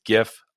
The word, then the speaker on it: gif: And that's right, I pronounce it with a hard G. All you soft G gif people out there, I don't know what a gif is outside of peanut butter. It's gif. I don't care.